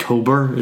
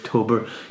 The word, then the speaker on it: Tobar: I think Tober is the right pronunciation. So yeah, Tober. You know, Tober Moor. – That's right. It's not like a tow bar that you have in the back of your caravan.